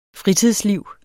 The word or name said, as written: fritidsliv